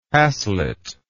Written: haslet

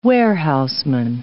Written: warehouseman

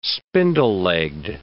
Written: spindle-legged

